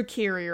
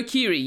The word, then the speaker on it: Keery: Jerry Carey are playing Keys and Utkarsh Ambudkar. I'm sorry if I've butchered his name. – No, that's okay. But you mean Joe Carey, right? – Joe Keery, yeah.